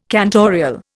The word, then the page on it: cantorial: Indian